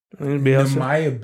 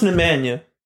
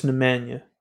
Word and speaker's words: Nemanja: Nemanja Bjelica? – No. It's pronounced Nemanja. It is. Okay. No. I mean, I watch enough soccer to know it's Nemanja.